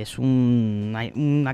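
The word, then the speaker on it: ESU (エス): Que es una catalogación muy japonesa. La historia S, o ESU como la pronuncian allí,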